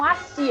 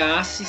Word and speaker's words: Assi: Isso, é, é assi sem, sem a, ah, a última sílaba não seria tônica, né? É, é assi. É que nem o sabão em pó, ácido, só que uma grafia diferente.